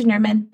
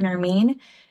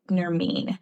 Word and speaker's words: Nermeen: But I will still respond to Nerman, but I really do prefer Nermeen because Nerman just sounds like vermin, and I don't think I want to associate myself with vermin. So yes, it's spelled like vermin, but it said Nermeen.